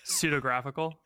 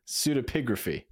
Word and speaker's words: pseudepigraphy: Pseudepigraphy. – Wait, okay, hold on. Say it one more time. – No. – Pseudographical? – Pseudepigraphy. – Pseudepigraphy. – Ancient pseudepigraphy was common and not always deceptive.